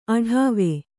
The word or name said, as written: ಅಡಾವೆ